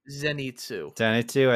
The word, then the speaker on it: Zenitsu: Zenitsu.